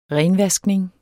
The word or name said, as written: renvaskning